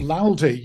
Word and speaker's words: laldy: Is it loud, as in that sort of Laldi? – Well, it's spelled L-A-L-D-Y. – Oh, no, different. – Okay. – Laldi, yeah, it's Scottish slang.